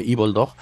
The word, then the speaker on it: Evildog: Pero mientras, aquí tenemos lo último de Evil Dog. Bueno, le decimos Evil Dog porque es la manera correcta de decirlo, pero se escribe Evil Dog, ¿vale?